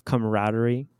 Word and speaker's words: camaraderie: there's that like sense of camaraderie. Camaraderie? Am I saying that right? – Yes. Camaraderie is the right word.